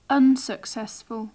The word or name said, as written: unsuccessful